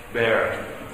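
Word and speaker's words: ber: I think it's pronounced bear. Is that right? Bear. Bear. It's just the brown fruit, greenish brown.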